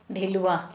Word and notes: ciʔaki: female

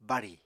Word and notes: bárì: apatani

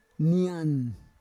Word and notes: nian: Roon

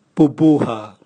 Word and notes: bubuha: Raga

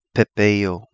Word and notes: Pepeio: Kapingamarangi

